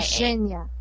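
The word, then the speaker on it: Zsenya: Z-E-N-S-Y-A. Does that sound right to anyone? Xenia? – No, it's Z-S-E-N-Y-A. – Xenia.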